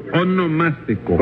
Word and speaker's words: onomástico: Sí, eso mismo, su monoelástico. – No, su onomástico.